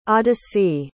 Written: audyssie

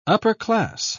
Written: upper-class

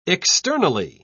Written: externally

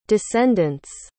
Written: descendents

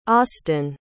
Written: austyn

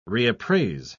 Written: reappraise

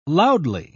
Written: loudly

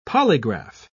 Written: polygraph

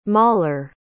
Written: mauler

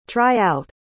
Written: try-out

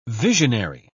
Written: visionary

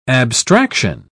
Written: abstraction

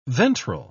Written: ventral

